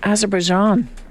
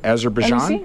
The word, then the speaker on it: Azerbaijan: Azerbaijan. – A- Azerbaijan.